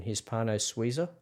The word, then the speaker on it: Hispano Suiza: Correct me if I'm wrong on pronunciation, Hispano Suiza. – Yep.